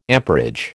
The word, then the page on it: amperage: American English